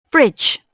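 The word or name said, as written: bridge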